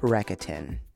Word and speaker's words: Rakuten: Today's episode is also sponsored by Rakuten.